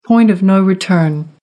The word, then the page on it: point of no return: American English